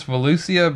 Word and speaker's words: Volusia: Volusius? – Volusius. Like, apostrophe S. Yeah. Say it. – So it's Volusia, but with an apostrophe S.